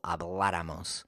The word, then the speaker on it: habláramos: And if you think about the word habláramos, it's stressed on the third to the last syllable, habláramos.